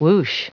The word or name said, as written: whoosh